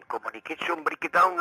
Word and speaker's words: Communication Breakdown: Communication Breakdown no creo que lo he dicho mal. Lo has dicho perfectamente.